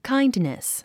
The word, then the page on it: kindness: káindnis; カァインドネス